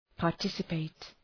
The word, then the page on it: participate: {pɑ:r’tısə,peıt}